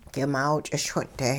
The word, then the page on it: k'imaaw ch'ischwin'-te: hupa